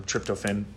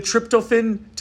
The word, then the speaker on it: tryptophan: She probably knows the term tryptophan or how to correctly pronounce it.